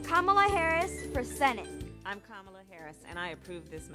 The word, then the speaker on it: Kamala: All right, so as she pointed out, and our babies and their baby voices pointed out, her name is pronounced Kamala, like a comma, and then la.